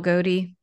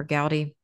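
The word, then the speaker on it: Gowdie: It's called The Visions of Isabel Gowdy or Gowdy. It's pronounced different ways depending on where you live.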